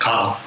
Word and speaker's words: Caudill: How do you pronounce it? – Caudill. – Caudill, okay.